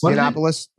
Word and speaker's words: Yiannopoulos: I can't pronounce his last name. Milo Yiannopoulos? – Yiannopoulos, yeah.